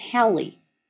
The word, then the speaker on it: Halley: Perhaps the best known of all comets is Halley, correctly pronounced so that it rhymes with rally, not daily.